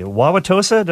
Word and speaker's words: Wauwatosa: Wauwatosa, did I say that right? – That's correct, yes.